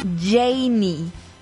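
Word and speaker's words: Jeynny: Y se escribe así, J-E y latina, doble N-Y, Janie Capistrán.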